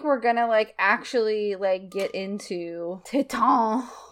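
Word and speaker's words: Titane: I want to say Titan, but Julia Ducournau said Titan. So I'm going to say that she is right. That's how we're going to be pronouncing it.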